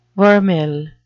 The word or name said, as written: vermeil